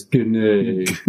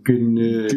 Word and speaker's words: Genet: I would definitely assume it was Genet. – The T is silent. – Every time I read it in my head, I go, Genet. – Genet. – But it could be Genet.